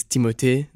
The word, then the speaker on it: Timothée: So the way it was meant to be pronounced is Timothée.